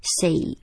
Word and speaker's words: safe: 好，念 safe 的时候哈，因为 S-A-F-E 什么 A 什么 E 那个 E 不发音的时候，这个 A 要念 A Save Save.